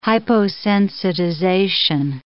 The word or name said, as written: hyposensitization